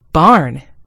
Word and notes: barn: British English

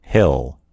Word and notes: hill: American English

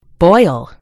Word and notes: boil: American English

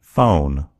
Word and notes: phone: American English

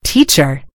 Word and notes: teacher: British English

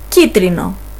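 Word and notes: yellow: Greek

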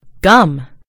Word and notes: gum: British English

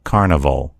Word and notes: carnival: American English

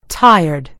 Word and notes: tired: American English

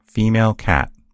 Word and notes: cat: American English

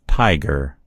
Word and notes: tiger: American English

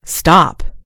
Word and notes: stop: American English

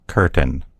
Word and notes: curtain: American English

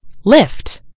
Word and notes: lift: American English

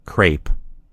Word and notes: crepe: American English

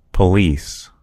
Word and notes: police: American English

